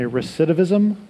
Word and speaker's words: recidivism: I'm going to use a word here that I always mispronounce, so bear with me. Recidivism? – You got it.